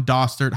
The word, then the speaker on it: Dostert: On the show today, we have Alan Dostert. How are you today? Is that how you pronounce the last name? – You're right.